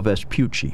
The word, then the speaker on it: Vespucci: Vespucci, am I saying it right? – Amerigo Vespucci. – Vespucci, yes.